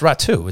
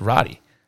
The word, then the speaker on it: Raty: I thought I said it's pronounced Ratu. It's not pronounced Roddy. It's spelled R-A-T-Y, but it's Ratu.